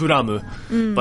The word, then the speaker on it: Blame: Like, I was like, dude, guys, like, there's going to be an anime movie for Blame, or Blam, because that's how it's actually pronounced. Yeah. It's Buramu, but it's spelled Blame, so I'm just going to call it Blame.